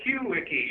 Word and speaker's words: QWiki: Yes, yeah, QWiki. Yes, that's the proper pronunciation for something that looks like Quickie on the screen, but that would be Willikai, which is the Texas pronunciation for Wheelie Key, Hawaiian word meaning turning and key.